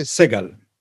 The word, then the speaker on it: Segal: Uh, uh how did you say his name? Segal? You say Segal. – Segal. Segal. Amit Segal.